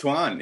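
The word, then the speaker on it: Antawn: It's pronounced Twan, yeah. – It's so weird. I don't like it. – It's like Dwayne Wade. It's just spelled weird.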